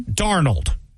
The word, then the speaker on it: Darnold: Darnold. Darnold. Got to say that right. Darnold. I don't know why I go the other weird way with it.